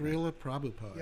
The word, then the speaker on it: Srila Prabhupada: Srila Prabhupada.